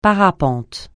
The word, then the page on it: parapente: francais